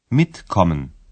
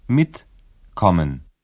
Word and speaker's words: mitkommen: В інфінітиві префікс і дієслово не розділяються, і наголос падає на перший склад. Mitkommen. Mitkommen.